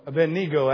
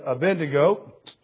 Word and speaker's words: Abednego: Abednego actually is the proper pronunciation. And I know we've always said a Bendigo or a Billy Goat, either which way you want to call it.